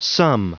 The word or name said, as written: sum